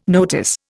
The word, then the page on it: notice: Indian